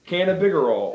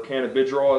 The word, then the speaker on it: cannabigerol: CBG, funny you should ask, is actually cannabigerol, or cannabidrol as some people pronounce it.